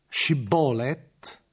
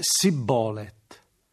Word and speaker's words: Shibboleth: Ebbene, gli Efraimiti pronunciano la parola Shibboleth, la pronunciano invece Sibboleth, senza la sh iniziale.